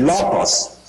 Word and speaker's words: logos: 因为太初有道，这个道这个字呢是上帝的语言嘛在希腊文是 logos，希腊文的读音是 logos。